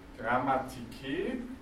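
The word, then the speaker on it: Grammatike (γραμματική): Grammatike. Wir haben die Betonung wieder über dem Eta hinten. Grammatike.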